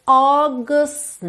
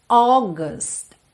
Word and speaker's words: August: One, não pronuncie o F final, tender August night, August, August. Atenção à pronúncia dessa palavra, ok? Porque nenhuma dessas vogais aqui soam exatamente como a gente lê mentalmente quando a gente ainda está muito influenciado pelo português, né? Pela nossa referência da língua portuguesa.